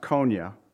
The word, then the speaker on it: Coniah: Here, if you look at it, it says Zedekiah, the son of Josiah, reigned instead of Coniah, or Coniah, many say, but it's pronounced Coniah, the son of Jehoiakim.